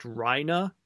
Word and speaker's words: Reiner: Because we were talking about how, you know, Reiner is pronounced Reina. I looked it up. Reina is how it's said in German.